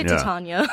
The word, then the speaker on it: Titania: You say it Titania? – Yeah, we say Titania.